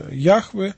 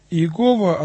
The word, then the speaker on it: Яхве: Но вот транслитерация этого имени как Иегова, она не совсем верная. Указанная форма возникла из попытки произносить согласные буквы этого имени так, как будто они были снабжены гласными знаками имени Адонай, что означает «господин», «владыка», что масореты внесли и в текст с указанием, что вместо Яхве следует читать Адонай.